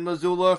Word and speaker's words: Missoula: So good job, Montana and Missoula, for shooting down this fucking wanker. – You pronounced Missoula correctly, but you fucked up tabled.